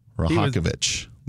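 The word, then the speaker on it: Rajaković: My bad. Completely blanking on the pronunciation. We'll get it at some point. Cavaliers topped the Nets today, 111-102. – I saw his press conference. He was not happy. – Rahakovic. There it is.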